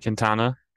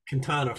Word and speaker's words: Quintana: How do you say his name? – Quintana. – Quintana from the Cardinals.